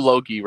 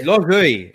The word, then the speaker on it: logoi: There's a few Logi references I missed out, but. – Oh yeah, and I hate that. I hate that pronunciation. That modern Greek pronunciation, logi. It's logoi. It's logoi.